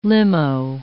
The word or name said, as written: limo